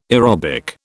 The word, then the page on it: aerobic: British English